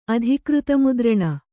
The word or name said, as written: ಅಧಿಕೃತಮುದ್ರಣ